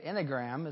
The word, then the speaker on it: Enneagram: See, if you pronounce the E and the A, it's Enneagram is what it is.